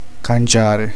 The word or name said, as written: cambiare